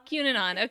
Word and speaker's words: QAnon: QAnon? QAnon.